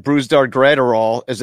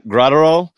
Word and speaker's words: Brusdar Graterol: Brusdar Graterol. Am I saying that right? – Yeah. – Graterol.